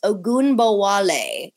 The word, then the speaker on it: Ogunbowale: I didn't even say it right. And I said it right so many times. Ogunbowale. It's so phonetic. I love it. It's how you spell it. It's how you say it.